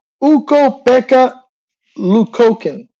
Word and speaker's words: Ukko-Pekka Luukkonen: En la portería de los Buffalo Sabres está Ukko-Pekka Luukkonen. No sé si lo dice bien, pero lo dije bien, pero bueno.